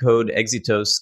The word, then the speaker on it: Code Exitos: And and with my awful New York accent, I'm going to butcher the name of your wonderful agency. How did Code Exitos get its name? – Yeah, that's a good question. And you said it just fine.